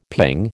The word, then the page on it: ping: American English